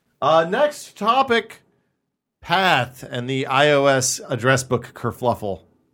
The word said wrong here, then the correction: kerfuffle: Kerfuffle. Is there an extra L there?